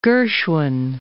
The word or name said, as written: Gershwin